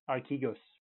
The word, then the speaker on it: Archegos: I think the pronunciation is Archegos, but yeah.